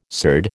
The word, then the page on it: surd: American English